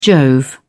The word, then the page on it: jove: British English